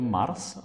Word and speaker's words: Mars: Jak se to vlastně vyslovuje? – Dobrý den. No vyslovuje se to Mars asi.